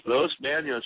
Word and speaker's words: Los Banos: I've been following you for a while, and I know you're up there from, I guess you call it, the pronunciation is Los Banos, right? – Los Banos, yeah.